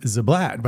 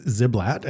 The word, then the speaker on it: Ziblatt: I always pronounced his name Ziblatt, but apparently it is Ziblatt, and so it's a minor difference, but...